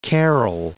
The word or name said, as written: Carol Stream